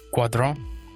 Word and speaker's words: Guadron: Guadron.